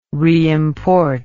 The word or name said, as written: reimport